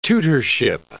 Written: tutorship

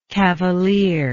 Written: cavalierly

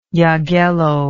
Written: Jagello

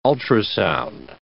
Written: ultrasound

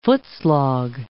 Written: footslog

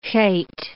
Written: heth

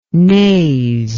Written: naze

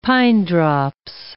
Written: pinedrops

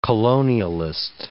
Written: colonialist

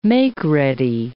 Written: makeready

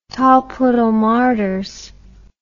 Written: tolpuddle martyrs

